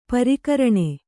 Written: ಪರಕರಣ

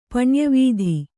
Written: ಪಣ್ಯವೀಧಿ

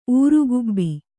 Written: ಊರುಗುಬ್ಬಿ

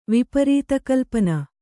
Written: ವಿಪರೀತಕಲ್ಪನೆ

